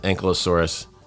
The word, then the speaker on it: Ankylosaurus: I know some people like to pronounce him differently too, but like we pronounce it Ankylosaurus.